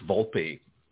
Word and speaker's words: Volpe: Rob, did I pronounce your name right? – It's Volpe. Thank you for asking.